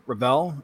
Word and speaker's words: Revell: It's kind of both. It's a bit like if you would ask Charles Leclerc how do you pronounce his name. He's fine with sort of either pronunciation. – Right. – A lot of people say Revell, and it's just like, well, I'll lean into that.